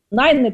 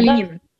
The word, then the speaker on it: Ленина: Кстати, ее вроде произносят все-таки как Ленину, потому что я смотрела вот на английском, все-таки она Ленина.